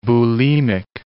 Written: bulimic

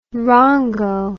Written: rongo

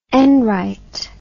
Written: enright

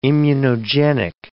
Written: immunogenic

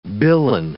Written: billon

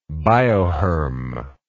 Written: bioherm

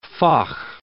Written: faugh

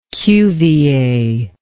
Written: Cuvier

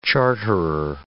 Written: charterer

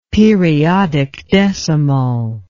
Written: periodic decimal

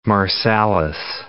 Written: Marsalis

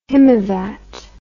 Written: himavat

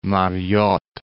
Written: Mariotte

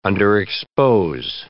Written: underexpose

